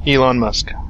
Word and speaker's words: Elon Musk: Elon Musk.